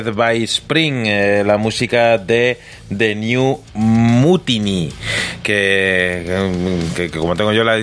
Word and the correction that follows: Mutiny: es más fácil, es más fácil decir eh, Muniti que Mutini. Mutiny, claro.